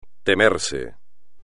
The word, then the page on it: temerse: espagnol